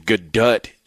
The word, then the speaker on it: Gadot: Actually, yeah, she leaves the T on there. It's Gadot. – Oh, does she? – Yeah. – Oh, well. – Right. Actually, Gadot, I believe, is the actual pronunciation.